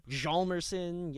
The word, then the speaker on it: Hjalmarsson: Hjalmarsson. – I think it's pronounced Hjalmarsson. I've been pronouncing it wrong my whole life. Hjalmarsson.